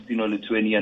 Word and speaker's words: Stinoletweny: Facebook, Twitter, Instagram, Stino Lituani.